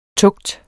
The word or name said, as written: tugt